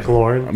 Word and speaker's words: McLaurin: The receiver McLaren or McCullen. What I can't. I always, McLaurin. McLaurin. I always pronounce his name wrong.